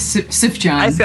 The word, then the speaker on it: Sufjan: I feel like there are multiple pronunciations. Like, I feel like it was introduced to me as Sifjan.